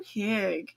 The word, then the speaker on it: hygge: this is so hygge. Well, that makes sense. – Yep. – Then you put it into Google and Google tells you how it's pronounced, and it's pronounced hygge.